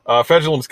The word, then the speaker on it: Fejedelem: They've got Fegidellum coming off. I added a syllable there. Excuse me. All right. Fegidellum's coming off the edge.